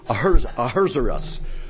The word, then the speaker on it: Ahasuerus: And yet the name of the king, Xerxes, and the Hebrew name is a strange name that sounds like somebody just sneezed, Ahasuerus. And one of the commentators said the best way to pronounce that is just to sneeze, and you get the idea.